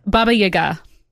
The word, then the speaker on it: Baba Yaga: Wait, so can you tell me a little more about how do you say it? – Baba Yaga. – or Baba Yaga, as those of us without any pronunciation training. – It's just where the emphasis is, you know?